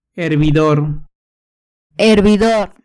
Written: hervidor

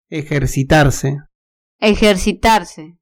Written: ejercitarse

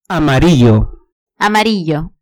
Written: amarillo

